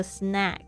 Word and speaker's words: snack: 这个 snack s n a c k 这个 a 发 a 的声音，但是呢 snake。